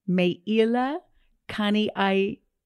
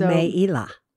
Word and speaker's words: Meila Kaniaikala: It's actually easy to pronounce Hawaiian names. You know why? – Why? – All of the syllables, all the letters are flat. They're all as you read them. E is E, A is A, I is I. – So Meila. – I did do that. Meila. – Meila. – Meila Kani A-E-A Kala.